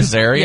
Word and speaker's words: Azaria: What about Hank Arazia? Am I saying that right? – Hank Azaria? – Yeah.